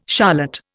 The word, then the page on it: charlotte: Indian